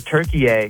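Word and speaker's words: Türkiye: Well, apparently we all pronounce Turkey the country wrong. Because in the World Cup, they're Turkey-A and not Turkey.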